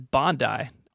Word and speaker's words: Bondi: We also need to correct the pronunciation of the first color iMac. Thank you to the entire nation of Australia for writing me to tell me that it is Bondi.